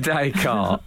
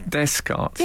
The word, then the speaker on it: Descartes: René Descartes, the philosopher, was described by Al as Descartes instead of Descartes. It's a simple mispronunciation.